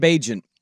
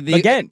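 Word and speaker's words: Bourguet: Then it's Bagent. – No, it's not Bagent. – Names can be pronounced weirdly no matter how they're spelled. No, I'm telling you, I just heard it. It's not Bagent. It's not Bager. It's Baguette.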